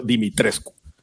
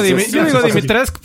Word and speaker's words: Dimitrescu: A Google Translate que pronunciara en rumano Dimitrescu y lo pronunció Dimitrescu. – Yo digo, Dimitrescu porque así lo hice en el juego, me vale más.